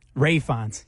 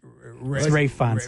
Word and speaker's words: Ralph Fiennes: Ralph, it's spelled like Ralph, though. – It is. – Yeah, yes, it is. – What is it called? – It's Ray Fiennes.